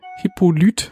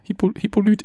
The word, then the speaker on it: Hippolyte: Aber auch dieser andere Darsteller, der den in Love Interest und gleichzeitig den Polizeichef spielt, der heißt, ich kann es nicht aussprechen, Hippolyte. – Oh Gott. – H-I-P-P-O, also Hippo, und dann L-Y-T-E. Das ist sein Vorname. Hippolyte.